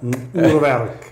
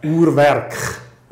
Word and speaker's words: Urwerk: Urwerk. – Urwerk. – You want the Swiss German version? – Yeah. – Urwerk. – Okay. – That's, you know, the Swiss German version, Urwerk.